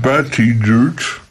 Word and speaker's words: Buttigieg: Zadałem sobie trud, żeby sprawdzić, jak to się czyta i się to jest coś w rodzaju Buttigieg, tak to czyta się sam zainteresowany.